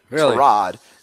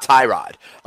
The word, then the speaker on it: Tyrod: Apparently it's really? Tarod, not Tyrod. Tarod.